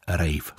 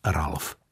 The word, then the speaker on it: Ralph: Ano, říkáš Ralph Fiennes a tak to má znít. Tedy alespoň podle našich zdrojů, sám hrdina příběhů z kalendáře má nejraději tuto výslovnost svého křestního jména. Snad nám promine, když mu na vlnách Českého rozhlasu budeme trochu česky říkat Ralf.